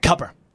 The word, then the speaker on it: Cooper: It's spelled Cooper, but it's pronounced like Southern, like Cupper.